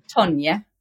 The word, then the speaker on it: Tonje: It's weird since I'm Norwegian. It's Tonje.